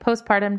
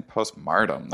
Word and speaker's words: postpartum: You're saying it right. Postpartum depression. – Postpartum depression. Mm-hmm. What was that? I was saying postmartum. That's like after death, isn't it?